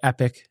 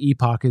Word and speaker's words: Epoch: So I think that if I remember correctly, based on some earlier conversations with the editor in chief, that the correct pronunciation in America is Epoch. – Okay. – Right. – Epoch. – So, overseas, they call it Epoch, and then a lot of Americans call it Epic.